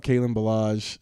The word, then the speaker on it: Kalen Ballage: All I know is that I saw a tweet today about Kalen Ballage.